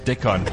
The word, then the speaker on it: Dickon: So, Dickon, how do you spell that? Dickon. – D-I-C-K-O-N. Yes.